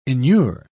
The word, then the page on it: enure: British English